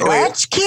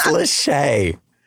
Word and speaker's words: Lachey: Is it Latchkey or is it Lahey? I'm looking at this name going, how do you pronounce this name? – It's Lachey.